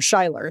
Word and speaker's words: Schuyler: George Schuyler, or Schuyler, if you pronounce it that way, is another example from the Pittsburgh Courier.